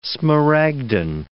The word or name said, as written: smaragdine